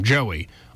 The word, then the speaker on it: Joey: That's my name, Joey. Online, just spell it out when you check out, J-O-E-Y.